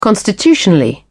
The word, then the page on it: constitutionally: British English